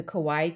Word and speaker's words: kawaii: The kawaii cakes. – Hawaii. – Kawaii. – I feel like you have to say it like that.